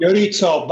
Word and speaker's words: Iorizzo: Iorizzo, but it's okay.